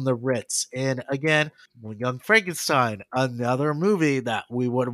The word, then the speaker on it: Frankenstein: That's Frankenstein. My name, it's pronounced Frankenstein.